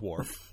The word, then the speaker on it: Worf: Well, Red Dwarf rhymes with Worf. So we established that. It is literally the only thing to rhyme with Worf.